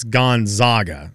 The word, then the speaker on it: Gonzaga: It's Gonzaga. – That's so weird. – Is that what it is? Or they say I don't even know how they say it wrong. Gonzaga.